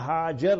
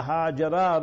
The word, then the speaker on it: Hajar: Then he gave a gift, remember, to Nabi Ibrahim alayhi salatu salam, Sayyida Sara radiallahu anha, and it was Sayyida Hajar radiallahu anha, Sayyida Hajara radiallahu anha. Both ways you pronounce it, Bukhari Sharif, other hadith kitab.